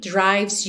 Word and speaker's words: drives: Like a heartbeat drives you mad. Drives you. Atenção que o E de drives também não é pronunciado, você vai do V direto pro S.